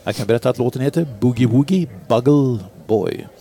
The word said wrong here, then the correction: bugle: Eller den heter inte Buggle, den heter Bugle.